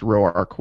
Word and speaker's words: Roark: Yeah, I think it is pronounced Roark, which I learned this week.